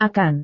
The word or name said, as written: akan